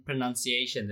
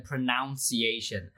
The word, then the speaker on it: pronunciation: 好像是 pronounce 跟 pronunciation，对不对？ – 对，因为这两个字其实是要给你们的，因为我从来没有教过发音。 – 这两个字有要特别注意，就是很多人会乱发音。 – 怎么说？ – 因为它的动词是念 pronounce，可是很多名词就会念成 pronunciation，大家其实是念 pronunciation。